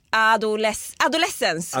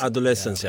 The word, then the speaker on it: Adolescence: Hanif, kan du hjälpa mig att uttala det här? Jag stod framför spegeln och tränade igår. Ados... – Vad sa jag? Tänk Evanescence. – Evanescence. Adolescence. – Adolescence, ja.